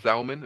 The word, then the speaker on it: salmon: Let me say this word right, because I pronounce the L in salmon, and people get on me for it all the time. – Are you stupid? – Salmon. – It's mad effort.